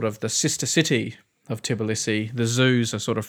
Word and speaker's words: Tbilisi: Tbilisi. Is that how you pronounce it? Capital of Georgia. – Oh, yeah, Tbilisi.